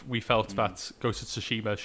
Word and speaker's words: Tsushima: There's so much to talk about with the Ghost of Tsushima. – Oh my god! Please say that again. – Ghost of Tsushima.